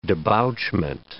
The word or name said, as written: debouchment